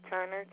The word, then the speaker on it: Turner: The account is Connie, C-O-N-N-I-E, Turner, T-U-R-N-E-R.